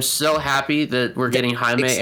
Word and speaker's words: Jaime: Is that how his name is pronounced? – Jaime, yes. – It's not Jamie? – No. – It says Jaime Reyes to me there. – No, it's Jaime.